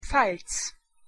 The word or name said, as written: Pfalz